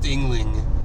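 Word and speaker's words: Yuengling: Let me know specifically why it's pronounced Yingling.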